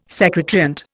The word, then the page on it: secant: Indian